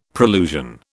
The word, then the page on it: prolusion: British English